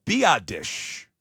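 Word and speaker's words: Biadasz: He says it is pronounced Biadish.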